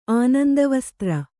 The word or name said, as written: ಆನಂದವಸ್ತ್ರ